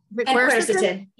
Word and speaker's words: quercetin: There's another quercetin? One that I can't pronounce. What is it? – Quercetin. – Yes, quercetin.